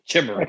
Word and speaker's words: Chimera: This is your nominees for best sim strategy game: Crusader Kings 3, Desperados 3, Gears Tactics, Microsoft Flight Simulator, and XCOM Chimera Squad. I fucking knew I was gonna say it like that. – Chimera. – XCOM Chimera Squad. – Chimera.